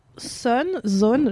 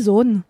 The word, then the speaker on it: Sohn: Donc mon kiff est une BD d'une autrice qui s'appelle Lily Zohn, j'ai envie de le dire à l'allemande parce que ça s'écrit S-O-H-N, Lily Zohn, et qu'est-ce qu'il y a? – Mais je te raconte après.